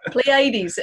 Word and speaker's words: Pleiades: Pleiades.